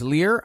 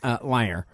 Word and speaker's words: lyre: But the thing is that fiddles actually weren't invented yet when Nero was in power, so he actually played a lyre, which many people erroneously pronounce as lyre, but it is actually pronounced lyre.